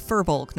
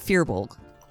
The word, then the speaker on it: firbolg: Adventure Zone pronounces it correctly. It's firbolg, not fearbolg.